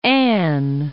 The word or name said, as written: Ann, Cape